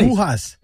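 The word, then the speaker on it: bujías: It's with the B as in boy. – Bujas.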